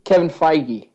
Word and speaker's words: Feige: What's his name, Kevin Feige? – Feige. Yeah. Are you talking about the head of Marvel? – Yeah. – Kevin Feige. Okay, is how you say it.